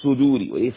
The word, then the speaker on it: صدور: صدوري وليس صدوري سو سو سو تضم الصاد صدوري صدور الناس الذي يوسوس في صدور الناس اتفقنا على الغنة في النون لأنها مشددة والمد في صدور الناس.